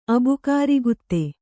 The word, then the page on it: ಅಬುಕಾರಿಗುತ್ತೆ: abukārigutte; Kannada